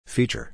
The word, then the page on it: feature: long-i